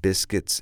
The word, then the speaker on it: biscuit: Now remember how we pronounce biscuit. Biscuit. The problem is it's spelled B-I-S-C-U-I-T. Okay, but don't worry about that. Just say biscuit.